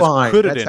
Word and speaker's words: Kuririn: I mean, his name is Kuririn, not Krillin. – That's how you say it, okay? You want to roll your R's?